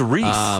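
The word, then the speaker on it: Rhys: And it is Reese. It rhymes with peace.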